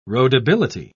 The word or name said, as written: roadability